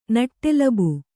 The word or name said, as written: ನಟ್ಟೆಲಬು